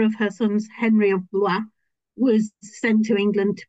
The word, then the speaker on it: Blois: I speak French, but I can't pronounce Blois. It always sounds like I'm throwing up. – I do the same thing, Blois. Blois. – It just sounds like you're throwing up.